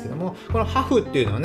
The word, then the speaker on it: HAFH: でね、えー、一つ最後にね、おすすめのサービス、昔紹介したんですけど、ハーフ。ハフっていうね、 HAFH って読みにくいんですけど、ハフ、